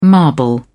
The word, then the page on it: marble: British English